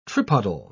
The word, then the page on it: tripodal: British English